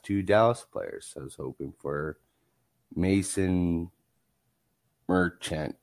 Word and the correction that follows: Marchment: Mason Marchment.